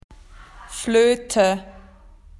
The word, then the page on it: whistle: Australian English